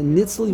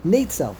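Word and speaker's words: Netzel (נצל): The Mesivt over here says the correct pronunciation over here is. Let's see. It says over here, Netzel. Okay, it says Netzel.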